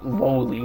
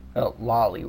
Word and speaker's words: lolly: Two cute lowly robots. Lolly robots. – そうと決まればとっとと片付ける。 – Two cute lolly robots. I've heard it pronounced both ways, but yeah, it's lolly.